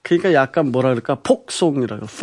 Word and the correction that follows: 포크송: 폭 아, 포크송. – 기타로. – 아, 포크소. – 예, 폭. 아, 그 발음 그 발음 트 발음 뒤 발음 잘 안 돼요.